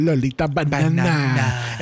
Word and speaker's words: Lolita Banana: Lolita Banana. Es como que tengo que sobrepronunciar la N.